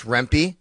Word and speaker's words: Rempe: Rempe, I believe it's Rempe. Yeah, I'm listening to the audio and it's Rempe.